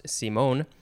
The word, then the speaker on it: Simon: Now we have Benet Simone scale, capital B-I-N-E-T, hyphen, capital S-I-M-O-N, Simone. It looks like Simon, but it's pronounced Simone.